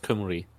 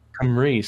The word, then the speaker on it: Cymru: It's pronounced Cymru. – Cymru, sorry. Thank you very much.